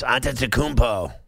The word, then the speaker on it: Antetokounmpo: Yes, Antetokounmpo.